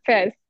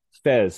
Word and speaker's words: Faiz: Faiz, is that how you pronounce the poet? – Fez. – Fez, excuse me.